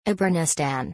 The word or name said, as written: Abrenistan